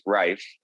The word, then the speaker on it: Reif: We have lived long enough for you to know my last name is pronounced Rife.